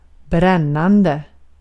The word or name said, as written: brännande